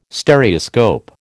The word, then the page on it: Stereoscope: American English